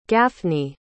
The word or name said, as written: Gaffney